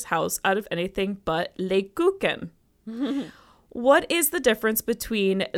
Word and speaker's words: Lebkuchen: Is that how you pronounce that? – Lebkuchen. – Lebkuchen.